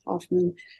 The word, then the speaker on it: Hofmann: Well, I have my own website, and it's actually my name, Mel Hofmann.